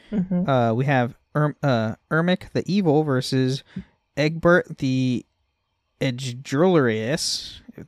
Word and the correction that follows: egregious: Egregious words. – I'm hearing a pirate's quote in my head. It's pronounced egregious.